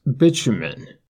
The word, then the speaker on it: bitumen: And, as you've noticed, I pronounce it one way, but it can also be pronounced bitumen, the British pronunciation. Neither is wrong, just different.